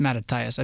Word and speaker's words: Mattathias: Now, I don't think Matthias is a bad guy. It's Mattathias. I've said it wrong.